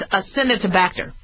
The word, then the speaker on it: Acinetobacter: I know, I used to mispronounce it as Acinetobacter, but that's not how to pronounce it. It's Acinetobacter.